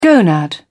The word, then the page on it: gonad: British English